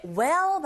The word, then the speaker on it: well: 근데 well 발음하실 때 이 L 사운드가 이게 다크 L 사운드라고 해서 여러분들이 발음하실 때 그냥 well이 아니라 혀끝이 이렇게 올라가면서 이 앞니 뒤에 붙여야 돼요. 그래서 정확한 발음이 well. 제 입을 보시면 well 하가 네, 혀가 윗니 뒤에 붙게 돼요. well이 아니라 well, ripe.